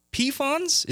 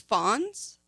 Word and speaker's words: Pfons: I think it's Fons. – Oh, Fons. – Fon and Still is their last name. – Ah, that makes sense. The P is silent.